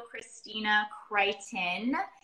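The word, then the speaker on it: Kristina Krykhtin: For those of you that do not know Christina Crichton, I said that right? – Perfect.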